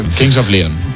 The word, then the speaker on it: Kings of Leon: Ya hemos tenido a Paco León, pues ahora a Kings of Leon. – Que es King of Leon, of Lion, no sé cómo se dice, la verdad, porque... – Kings of Leon.